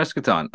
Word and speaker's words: Eschaton: No, yeah, Popcorn Eschaton.